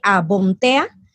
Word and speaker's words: Bomthea: Bueno, me pueden seguir en arroba somos Bomtea. Bomtea se escribe B-O-M-T-H-E-A, Bomtea.